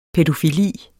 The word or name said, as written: pædofili